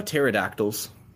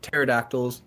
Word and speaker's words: pterodactyl: So, right, pterodactyls, pterosaurs, right? Ter, the prefix ter, P-T-E-R, means wing. Pterodactyl means wing finger. Pterosaur, I believe, means winged lizard. And so, ter, right? The P is silent, means wing. Helicopter ends in P-T-E-R.